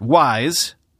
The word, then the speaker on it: WSE: Cerebras is calling this new monster chip the Wafer Scale Engine, or WSE, although they want you to pronounce that WISE for the purposes of future marketing.